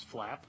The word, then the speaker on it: FLAP: Federal Lands Access Program, which can be pronounced as FLAP.